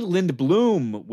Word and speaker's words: Lindblom: Lindblom, which I would like to say we got pronunciation clarification, and I was right.